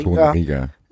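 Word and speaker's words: Torunarigha: Die nutzen ihre Chancen einfach extrem gut, haben auch, wie spricht man aus, Torunariga? – Torunariga.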